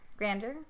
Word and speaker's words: grandeur: Such is his beauty and grandeur. Grandeur? – Grandeur. – Yeah. So said it right.